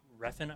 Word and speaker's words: Refn: Directed by Nicholas uh, Refn. I'm, sorry if I just butchered that. – I am so sorry. – I think it's Refn.